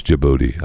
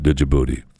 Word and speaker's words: Djibouti: I know it's Djibouti. I got it. But it starts with a D, so I call it Djibouti. They pronounce it wrong. They pronounce their country's name wrong.